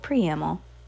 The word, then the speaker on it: preamble: He said without preamble. No. All right, well, since we're here. Yes, okay. How do you say that word? – Preamble. – Oh, preamble. Okay.